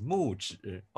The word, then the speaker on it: Muzhi: But my actual name is Muji, but proper pronunciation is Muji.